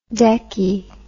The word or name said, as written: Deccie